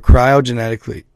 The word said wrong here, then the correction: cryogenically: Cryogenically. Why am I freaking brain scanning on that? Cryogenically. I keep saying genetically. Cryogenically, right?